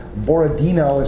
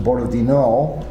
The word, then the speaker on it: Borodino: One is Borodino is actually pronounced Borodino. The accent is in the last syllable.